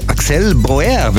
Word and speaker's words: Axel Bauer: Müsste eigentlich wahrscheinlich französisch Axel Bauer wird er wahrscheinlich ausgesprochen. 61 in Paris geboren und er ist tatsächlich französischer Sänger und Gitarrist.